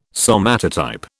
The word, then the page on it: somatotype: British English